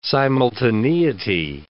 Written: simultaneity

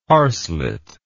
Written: harslet

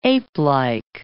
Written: apelike

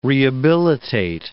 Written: rehabilitate

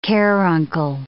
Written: caruncle

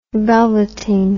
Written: velveting

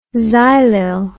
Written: xylyl

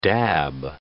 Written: dab

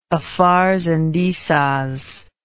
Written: Afars and Issas

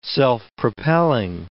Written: self-propelling

